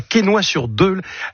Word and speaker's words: Quesnoy-sur-Deule: Tu habites à Quesnoy-sur-Deule. Est-ce que je l'ai bien prononcé? J'ai pas écorché le nom. – Impeccable.